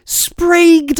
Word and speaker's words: Sprague: It's pronounced Sprague. Sprague de Camp.